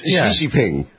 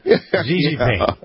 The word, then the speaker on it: Xi Jinping: like a poodle, Xi Xi. It's Xi Jinping. – Xi Jinping. I have to remember that. – Here's Xi Jinping.